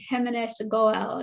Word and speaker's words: Himanish Goel: And today I have my friend Himanish Goel.